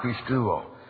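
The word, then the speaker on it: pisteuo: In the Greek language, saving faith is the verb pistuo, which is pronounced pistuo.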